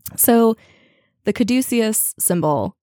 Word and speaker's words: Caduceus: Okay, so how do you say it? – So here's Google. – Caduceus. Caduceus. – All right, Caduceus.